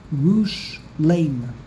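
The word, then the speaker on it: Yerushalayim (ירושלים): in Aramaic, the correct pronunciation is with a segel, Yerushalayim.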